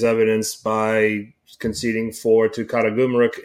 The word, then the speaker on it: Karagümrük: Sivasspor is coming off of a 4-3 defeat at Karagümrük. Did I pronounce that right? – Sounds good to me.